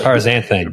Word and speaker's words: paraxanthine: Paraxanthine. I butchered it again. You say it. – Paraxanthine. – There you go. – You're trying to put the X in it. Don't worry about the X.